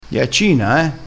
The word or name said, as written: ghiaccina